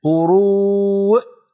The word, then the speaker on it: قروء: لا تقول يا هدى، لا تقولي قروء، لا تنطقي الهمزة مرتين، قروء فقط، أقفل الوترين الصوتيين.